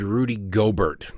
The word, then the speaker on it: Gobert: What happened was a player for the Jazz, a guy named Rudy Gobert, I believe is how you pronounce his name.